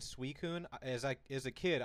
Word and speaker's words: Suicune: Pokédex thing always had the pronunciation thing like a dictionary, and they pronounced it Suicune.